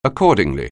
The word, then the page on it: accordingly: anglais